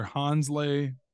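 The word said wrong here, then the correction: Hansle: Parchment. Is that correct? – Hansel Parchment. Excuse me. Sorry for the mispronunciation.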